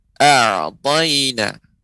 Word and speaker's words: أعطينا: pengucapan a'taina ain yang ketika pengucapannya dia ya lembut ya pengucapannya tidak tidak syiddah ya tapi dia apa namanya uh, eh, tawasud baini ya pengucapannya a'tayna a'tayna